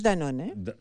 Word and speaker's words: Danone: En Francia decimos Danone. – Danone. Perquè la E no es pronuncia, bé, perquè no està accentuada, no? – Exacte.